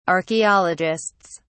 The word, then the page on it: archaeologists: British English